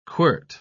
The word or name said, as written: quirt